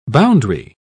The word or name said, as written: boundary